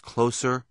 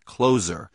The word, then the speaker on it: Closer: Yes, it's pronounced Closer, not Closer, which I guess would be a sequel to Glengarry Glen Ross.